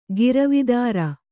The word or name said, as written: ಗಿರವಿದಾರ